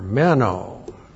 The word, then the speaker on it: meno: But there's another word that John uses over and over, meno. M-E-N, long O.